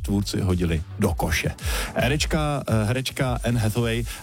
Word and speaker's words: Anne Hathaway: A teď ale něco pro vás, kdo jste fanoušci americké herečky, nádherné americké herečky Anne Hathaway, takhle ji vyslovují, a filmu Deník princezny.